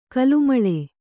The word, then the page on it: ಕಲುಮಳೆ: kalumaḷe